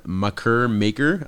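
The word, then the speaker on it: Makur Maker: Next topic, Macker Maker. I'm apologize if I'm pronouncing that incorrectly. – No, that's right, I'm pretty sure. – Macker Maker.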